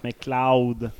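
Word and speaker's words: MacLeod: Ça se prononce McLeod.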